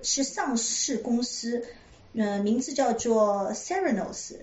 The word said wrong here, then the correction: Theranos: 我不确切它这个公司的名称的这个 pronunciation 发音对不对？它是 Theranos。